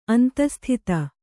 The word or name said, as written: ಅಂತಸ್ಥಿತ